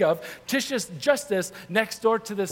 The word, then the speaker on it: Titius: Then Paul, he left the synagogue, like the spiritual center, and he went next door to the house of Titius is how you pronounce that.